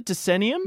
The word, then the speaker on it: Decennium: Now, did I say that right? Decennium? – You did. Yeah.